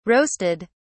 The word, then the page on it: roasted: British English